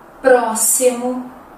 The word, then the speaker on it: próximo: Outro som que nós encontramos é o som do S. Por exemplo, as palavras máximo, próximo, texto se escrevem com X, mas tem som de S, ok?